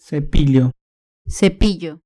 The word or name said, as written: cepillo